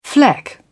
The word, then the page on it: fleck: British English